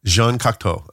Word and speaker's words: Jean Cocteau: Is that, how do you say Cocteau? – Jean Cocteau. – Okay. Jean Cocteau.